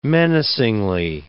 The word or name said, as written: menacingly